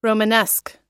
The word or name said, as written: romanesque